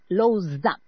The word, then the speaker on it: Lausanne: Se escribe Lausanne. Se pronuncia Lausanne.